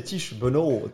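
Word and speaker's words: Tiesj Benoot: le premier, en termes de prononciation, c'est Tish Benoît.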